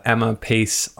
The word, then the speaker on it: Emma Pace: I have Emma Pace on the show, and I know I said that right. – Yeah, you did.